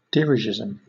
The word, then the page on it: dirigisme: British English